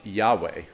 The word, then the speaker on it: Yahweh: In Hebrew, the fear of the Lord literally reads the fear of Yahweh. If you ever see YHWH, that's the Tetragrammaton, and it's a transliteration of the Hebrew letters Yod, He, Vav, He, and we pronounce it with the vowels as Yahweh.